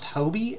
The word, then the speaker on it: Hobby: A former Texas governor was born in Moscow in 1917, a William P., and I believe it's pronounced Hobie, H-O-B-B-Y. I do not believe it's Hobby.